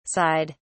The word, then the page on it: sighed: British English